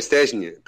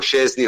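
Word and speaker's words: Szczęsny: No, vabbè, sarebbe Stesny, però insomma non, non diciamo Scesni così almeno eh, mettiamo d'accordo tutti, anche se la pronuncia corretta è Stesny.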